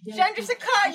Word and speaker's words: Chandrasekhar: Chandrasekhar, you pronounced it!